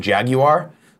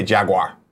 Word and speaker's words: Jaguar: If you're in UK and you pronounce it Jaguar, good for you. Here in America, we don't want to work that hard. We say Jaguar. Jaguar.